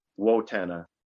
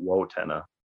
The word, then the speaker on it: Wotena: It sounds like antenna, but with a wo. It's like, hey, that's pretty simple. And I tried to explain to people how to pronounce for years, and she made it simple. Just like antenna, but Wotena.